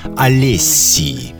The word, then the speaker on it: Alessiee: Олессии.